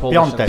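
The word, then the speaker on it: Piątek: It's pronounced Piontek. – Piontek. Piontek. – So it's like an O and an N instead of the A replaces it.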